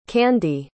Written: candie

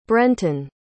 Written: brenten

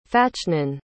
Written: Fachnan

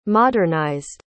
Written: modernized

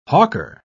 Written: hawker

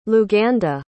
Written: luganda

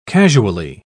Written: casually

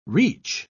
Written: reach

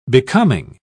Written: becoming

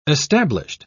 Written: established

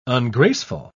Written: ungraceful

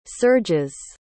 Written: surges

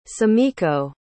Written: sumiko

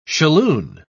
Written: shalloon

